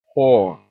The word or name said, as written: whore